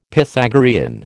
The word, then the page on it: Pythagorean: American English